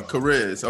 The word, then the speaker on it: Chariz: This is the views and opinions of the Chariz. – Charisma. Yes, like charisma. – Like charisma. Chariz. Okay.